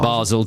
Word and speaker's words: Basel: Is it pronounced Basel? – Basel Division. Yeah, that's how it's pronounced.